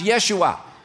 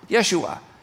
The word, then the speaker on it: Yeshua: but there's no J in Hebrew. It's pronounced Yeshua. Yeshua. So